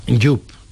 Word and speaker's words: Yoop: Yup, que se escribe Y-O-O-P.